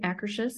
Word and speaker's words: Akershus: Am I saying that right? Akershus? Yep. Yeah. Okay.